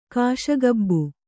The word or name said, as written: ಕಾಶಗಬ್ಬು